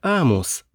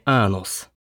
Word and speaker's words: Амус: Название планеты произносится как Амус, а не Анус.